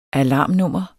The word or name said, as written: alarmnummer